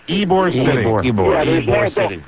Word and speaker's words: Ybor City: Ybor City. – The Y is, don't pronounce the Y.